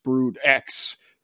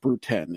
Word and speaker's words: Brood X: So this is, the famous one is not going to be pronounced Brood X. It's going to be pronounced Brood 10, isn't it? – Yeah, the Roman numerals.